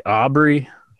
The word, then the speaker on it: Aubrey: And Aubrey, am I saying it right? Aubrey? – Yeah.